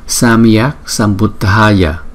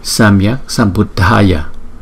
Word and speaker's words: Sambuddhaya: Samyak Sambuddhaya, Samyak Sambuddhaya. Chữ D và chữ H chúng ta đọc là D, D, D. Samyak Sambuddhaya.